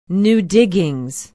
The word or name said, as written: new diggings